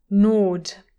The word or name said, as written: gnawed